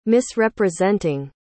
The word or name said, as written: misrepresenting